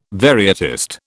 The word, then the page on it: varietist: British English